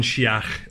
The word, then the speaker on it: Sheach: Have I said that correctly? – Almost. – Yeah. – So it's Gordon Sheach.